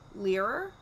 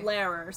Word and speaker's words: Learer: So, she was the girlfriend of a Nazi agent named Leerer? L-E-A-R-E-R. – Spell it again? – L-E-A-R-E-R. – Okay. – Leerer, something like that. – So, kind of like King Lear, but with an extra E.